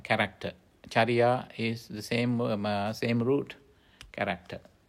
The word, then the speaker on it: cariya: Actually, that's called C-A-R-I-Y-A, charya, charya.